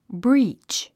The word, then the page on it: breach: bríːtʃ; ブリーチ